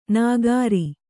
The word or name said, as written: ನಗರಿ